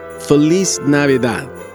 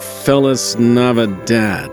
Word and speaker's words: Feliz Navidad: Well, first of all, it's pronounced Feliz Navidad, not Feliz Navidad.